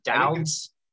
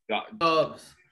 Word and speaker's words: Doubs: How do you pronounce that guy's last name? – Dowds? – Dubs. Dubs? Dubs. That's a chill last name, Dubs.